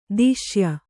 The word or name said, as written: ದೇಶೀಯ